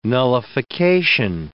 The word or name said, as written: nullification